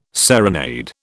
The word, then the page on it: serenade: British English